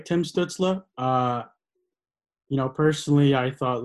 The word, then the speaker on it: Stützle: Stützle. – Tim, yeah. Stützle.